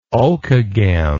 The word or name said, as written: Okeghem